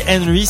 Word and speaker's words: HNRY: Quand je vous dis Henry, c'est un artiste dont le nom s'écrit H-N-R-Y.